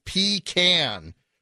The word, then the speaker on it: pecan: They have a new one called Pecan Resist, but they want you to pronounce it pecan, like we can.